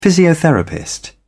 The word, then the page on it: physiotherapist: British English